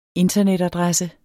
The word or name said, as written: internetadresse